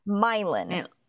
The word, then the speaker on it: Milan: locals in Ohio call Milan Milan.